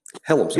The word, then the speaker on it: Hellams: Am I saying that right? Helms. DeMarco yeah, that's right.